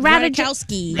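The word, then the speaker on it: Ratajkowski: How do we say her name again? – Radikowski. – Thank you.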